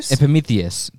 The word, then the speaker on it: Epimetheus: Zeus sent her Epimetheus? I don't know. How do you say that? – Epimetheus. – That sounds better.